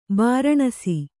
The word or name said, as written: ಬೂರ್ನೀಸು